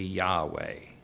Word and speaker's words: Yahweh: It means a self-existent or eternal or Jehovah, or the more modern pronunciation would be Yahweh. And I sort of prefer that one.